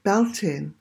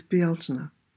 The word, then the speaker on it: Beltane: So we're looking at So starting with the name of this cottage, the word for the month of May is Beltane, or those who don't know how to spell it or pronounce it will call it Beltane, which it is not. It is Beltane.